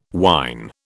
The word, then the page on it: wyn: British English